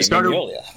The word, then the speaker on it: Mignola: Mignola. – It's easier to say if you actually don't look at it.